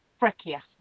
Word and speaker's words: Freccia: Yes, in Italy they pronounce the C's as a K, it's like a checker. – Right. – It depends on which, but I believe. It's Freccia, I think, is the correct pronunciation for that one.